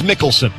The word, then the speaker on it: Mikkelsen: But I believe it is Michelson. Now, here's how it's spelled. M-I-K-K-E-L-S-E-N. So it's kind of confusing.